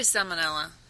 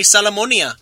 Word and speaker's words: salmonella: No, you said it right, but my friend at the convenience store goes, man, you're going to get me sick. You're going to give me salmonia.